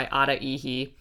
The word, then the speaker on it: Ada Ehi: It's either called I Celebrate or Celebration by Ada Ehi. It's spelled A-D-A-E-H-I is her name.